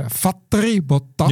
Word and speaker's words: Valtteri Bottas: En maakten dus inderdaad de connectie met Valtteri Bottas. Ja, zoals je het moet uitspreken op zijn Fins.